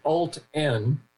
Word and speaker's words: Alten: It's the company is called Alt-N, which is spelled A-L-T-E-N.